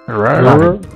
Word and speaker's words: Rare: Rare.